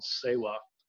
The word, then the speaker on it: SEWA: So in the very beginning, in 2014, we started working with a group called SEWA. It's an acronym, S-E-W-A, pronounced for Self-Employed Women's Association.